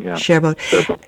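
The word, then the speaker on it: Gerbeaud: So early in the novel, we find out that Robert loves going to the Café Gerbaud. Is that how you say it? – Gerbaud.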